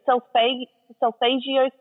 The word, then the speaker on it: Solfeggio: So when we're doing like a sound healing, because I tend to draw to the, is it Solfeggio scale? – Yes.